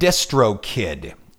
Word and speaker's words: DistroKid: I created an account at a website called DistroKid. D-I-S-T-R-O-K-I-D, DistroKid.